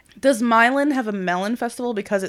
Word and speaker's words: Milan: Like two towns over from where I'm from, Sandusky, is Milan, not Milan, Milan, but it's spelled the same.